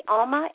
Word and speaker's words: ALMA: And ALMA, am I saying it correctly? ALMA, A-L-M-A? – You are. That's correct.